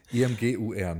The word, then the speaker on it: Imgur: Ähm, zum Vergleich ähm, schreibt er, er zahlt Imageur, nennt man, schrieb, spricht man das ja. aus? IMGUR, ne? – EM, IMGUR. Ja.